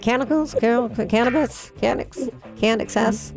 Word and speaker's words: Canucks: Oh, the Canucks.